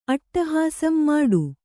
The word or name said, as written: ಅಟ್ಟಹಾಸಂ ಮಾಡು